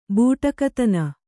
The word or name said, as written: ಬೂಟಕತನ